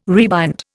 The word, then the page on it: rebind: Indian